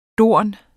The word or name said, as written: dorn